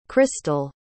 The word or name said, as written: Krystal